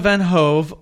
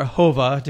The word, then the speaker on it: van Hove: And Eva Van Hove, or Hova, depending on who you talk to about pronunciation, directed this.